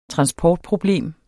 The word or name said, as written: transportproblem